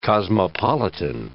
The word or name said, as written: cosmopolitan